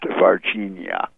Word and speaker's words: Varginha: Well, the Brazil crash, number one, occurred in 1996, and it is pronounced Varginha. – Thank you for correcting me. – So if you think of Virginia, the state or the name of the female, and substitute the I with an A, you have Varginha, and it was a small town of about 160,000 people.